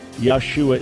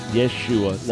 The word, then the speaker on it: Yeshua: All right, yeah, tell you what, you do have the correct answer in terms of Yahshua is not the pronunciation. The pronunciation of his name was Yeshua, not Yahshua.